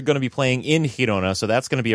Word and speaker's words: Girona: Girona, am I saying that right? – Girona. – Girona. – There you go.